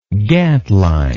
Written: gantline

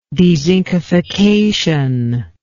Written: dezincification

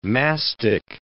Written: mastic

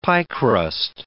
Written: piecrust

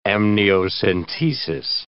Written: amniocentesis

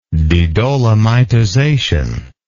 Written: dedolomitization

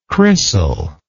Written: crissal

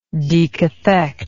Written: decathect

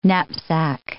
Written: knapsack

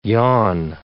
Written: yon

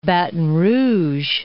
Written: Baton Rouge

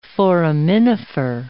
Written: foraminifer

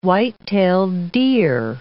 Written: white-tailed deer

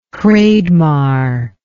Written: Hreidmar

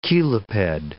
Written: cheliped

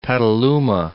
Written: Petaluma

